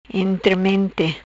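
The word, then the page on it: Intremente: Patuá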